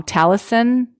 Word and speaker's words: Taliesin: So he had a studio called, I'm probably going to pronounce it wrong, Taliesin. It's like a Welsh name in Wisconsin.